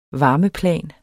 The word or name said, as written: varmeplan